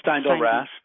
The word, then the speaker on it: Steindl-Rast: And so I was thinking about I always feel like I'm saying his last name wrong, Brother David Steindl-Rast. – Rast, thank you.